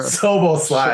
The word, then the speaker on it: Szoboszlai: Szoboszlai.